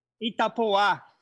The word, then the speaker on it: Itapoa: Okay, and how do you say the project properly? Itapoa? – Itapoa. – Itapoa, okay. – Itapoa. – Itapoa. Yes. All right, great.